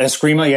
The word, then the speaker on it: Eskrima: And then I think the other one was like Kali, or is it Eskrima? Is that how they pronounce it? – Yeah, Eskrima. Yeah.